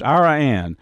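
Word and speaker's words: Iraan: And, you know, next week I'm going to be going out to West Texas to a town called Ira Ann. it's almost spelled like Iran. Matter of fact, I've made the mistake of calling it Iran. But it's Ira Ann out in far West Texas, mule deer season.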